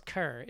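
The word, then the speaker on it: Kher: and I don't know if it's Kerr or Kerr. – Kerr? – I think it's Kerr. It's K-H-E-R.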